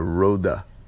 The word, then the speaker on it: Rode: Actually, the pronunciation would be better to call it a Rode mic. Rode, R-O-D-E, when you're on the road, R-O-A-D.